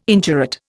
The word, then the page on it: indurate: Indian